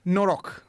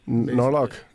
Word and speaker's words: Noroc: Noroc, which roughly translates to in good luck. – Noroc.